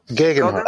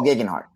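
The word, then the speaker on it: Geoghegan Hart: How is that you pronounce the last name from the Sky Guy? Theo Gegenhardt. – Oh, Gegenhardt.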